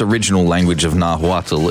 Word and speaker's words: Nahuatl: In its original language, let's see if I can pronounce that. Nahuatl. – Nahuatl pronunciation. Come on. – Nahuatl. Yeah, cool. It's not spelt like that, trust me.